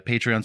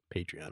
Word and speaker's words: Patreon: But I'm pretty sure it's Patreon because it's like a patron.